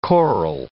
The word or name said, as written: coral